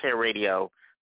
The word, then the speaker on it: HairRadio: It's pronounced Hair Radio, but it has one R in the middle.